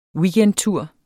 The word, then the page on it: weekendtur: [ ˈwiːgεnd- ]; [ ˈviːgεnd- ]